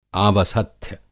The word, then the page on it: आवसथ्य: Hindi